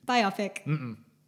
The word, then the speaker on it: biopic: It's it's a biography picture. It's a biopic. – Biopic. – Nope. – Biopic. – I would like to establish that the official working title pronunciation of that word is biopic.